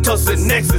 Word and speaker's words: Tussionex: Forty dollars for just one ounce, Tussionex is how it's pronounced.